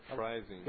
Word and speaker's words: Freising: Is that how you pronounce that? – Freising. – Yeah, I like the way you say it better.